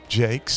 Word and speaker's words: Jacques: My good friend Michael Jacques from Springfield, Missouri.